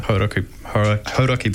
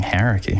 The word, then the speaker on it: Hauraki: Well, yeah, because you get used to saying Hauraki. – Hauraki. – Like when you grow up here and then you leave and it's like, no, it's time to learn how to say it properly.